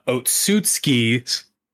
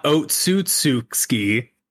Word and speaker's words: Otsutsuki: which is Kaguya Otsutsuki, not Otsutsuki. That's not how you pronounce it.